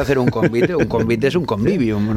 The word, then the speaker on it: convivium: Los romanos, a ver si lo digo bien, convivium. – Sí, convivium, que de hecho hay muchas palabras que hoy utilizamos, vienen precisamente de ahí.